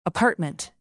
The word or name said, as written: apartment